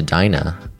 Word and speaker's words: Dinah: Howard's marriage to Dinah, pronounced Dina, Adina Manziel, is played by Adina Manziel.